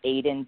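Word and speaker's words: Aydian: If you just look up my name, Aiden Dowling, it's spelled a little different.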